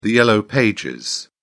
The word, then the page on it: yellow pages: British English